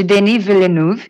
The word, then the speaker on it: Denis Villeneuve: Dentre os 50 filmes anunciados, temos Duna, de Denis Villeneuve, The Power of the Dog, de Jane Campion e Spencer, de Pablo Larraín, estrelando Kristen Stewart no papel da Lady Di.